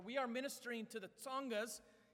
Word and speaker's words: Tsongas: It's not the Tongas. The Tongas are another people group in Zambia. We are ministering to the Tsongas. So think of the last syllable in cats, and that's the sound you put at the beginning, the Tsongas in rural South Africa.